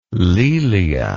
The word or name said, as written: laelia